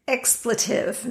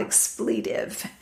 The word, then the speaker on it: expletive: It's expletive, not expletive. Emphasis on the wrong syllable.